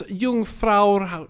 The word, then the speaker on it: Jungfrau: And what's Jungfrau, how do you pronounce it slowly? – Jungfrau. It's J-U-N-G-F-R-A-U, Railways.